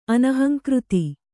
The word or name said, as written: ಅನಹಂಕೃತಿ